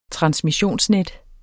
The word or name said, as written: transmissionsnet